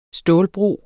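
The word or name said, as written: STÅLBRO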